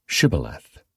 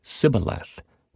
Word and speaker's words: Shibboleth: If the man said, "No, I'm not," they would tell him to say, "Shibboleth." If he was from Ephraim, he would say Sibboleth, because people from Ephraim cannot pronounce the word correctly.